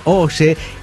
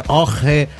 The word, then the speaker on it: Ojje: Vengo a decirles que en mi pueblo, Santiago del Oye, que seguramente lo esté pronunciando mal porque se escribe O-J-J-E. – Sí. – Esto, Oye, no se pronuncia. – No. – Esto debe ser Oje o Oje. Oje, Oje.